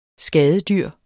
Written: skadedyr

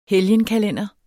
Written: helgenkalender

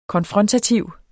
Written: konfrontativ

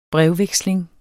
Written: brevveksling

